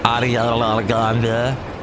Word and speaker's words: Ariana Grande: Played the new Ariana Grande, and that's how you say it, Ariana Grande.